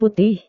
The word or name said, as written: putih